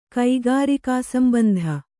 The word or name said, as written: ಕೈಗಾರಿಕಾಸಂಬಂಧ